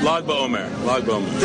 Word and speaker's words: Lag BaOmer: And especially in times of emergency or in times of crisis or even in times like this, like Lag BaOmer. Am I saying that right? – Lag BaOmer.